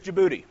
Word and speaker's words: Djibouti: And here's Djibouti. It rhymes with certain things, like your booty.